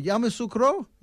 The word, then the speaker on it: Yamoussoukro: Yamoussoukro? – Yamoussoukro, yes.